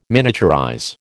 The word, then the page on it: miniaturize: American English